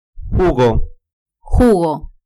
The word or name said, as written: jugo